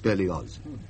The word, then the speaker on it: Berlioz: I'm sorry, but the name is Berlioz. German in origin. The family has lived in Savoy for generations, of course, but it is pronounced Berlioz.